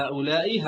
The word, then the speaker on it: هؤلاء: فلو ركزت في كلمة هؤلاء، دائما أنت تنطق كلمة هؤلاء بالكسر دائما، فلا نستطيع أن نقول هؤلاء ولا هؤلاء، وإنما هؤلاء، هؤلاء دائما، لأن هؤلاء من الكلمات المبنية التي لا يتغير شكل آخرها مهما تغير موقعها في الجملة.